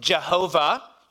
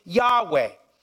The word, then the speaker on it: Yahweh: Some have tried to figure out how to pronounce it. Some have, through Latin influence, have pronounced it Jehovah. You might have heard that, Jehovah. That's from some Latin influence. Some have taken the vowels from Adonai and put them between YHWH and pronounced it Yahweh.